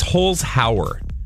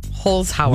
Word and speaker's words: Holzhauer: Also, it's Holzhauer, James. – Holzhauer. – We've been saying it wrong.